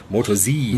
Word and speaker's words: Moto Z: And the answer is the Moto Z, or I think they pronounce it Moto Z.